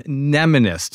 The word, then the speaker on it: mnemonist: You may have heard the term mnemonist, spelled with an M-N, like Johnny Mnemonic or a mnemonic device.